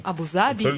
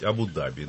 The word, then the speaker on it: Абу-Даби: Если исходить из абсолютно литературного варианта, не разговорного, то лучше говорить Абу-Даби. Но ударение надо ставить на А, а не на А.